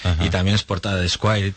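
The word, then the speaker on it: Esquire: Es un nombre muy difícil, se pronuncia Squire.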